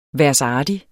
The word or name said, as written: værsartig